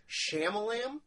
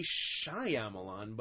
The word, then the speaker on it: Shyamalan: I believe it's pronounced Shyamalam. – Well, it should be Shyamalan, but you just forget about the Y in there for some reason.